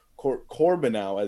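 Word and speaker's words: Corbeanu: Obviously, Theo Corbinau, as he apparently says his pronunciation is, and if he's going to say that, I will certainly trust him on that because we have had that discussion. Corbinau scores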